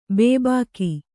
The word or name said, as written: ಬೊಬ್ಬಿಕ್ಕು